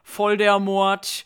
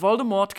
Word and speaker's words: Voldemort: Es wird immer von Voldemort geredet, in einem Tweet hat J.K. Rowling aber verkündet, dass es eigentlich Voldemort heißt. Das T am Ende ist stumm,